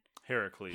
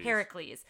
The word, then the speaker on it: Heracles: Heracles. – Heracles.